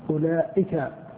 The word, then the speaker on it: أولئك: أولئك أولئك أولئك أولئك بهمزة مضمومة ثم واو زائدة تكتب ولا تنطق ثم لام ثم ألف ثم لام ثم كاف أولئك.